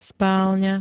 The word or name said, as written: spálňa